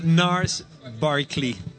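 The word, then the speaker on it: Gnarls Barkley: Si chiamano Nars Barkley. – Quindi gno gno niente. – No, non si pronuncia, sì, è con la N.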